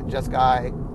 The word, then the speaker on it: Jeskai: And we named them, let's see, the white one was Abzan, the blue one, centered one, obviously, was Jeskai, is that right, Jeskai? Yeah, Jeskai.